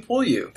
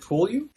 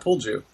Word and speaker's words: Pulyu: Is it Pulyu? – It is Pulyu, like push me, Pulyu. – All right, not Pulju.